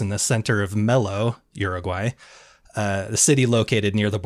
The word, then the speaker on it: Uruguay: This happened down in Uruguay.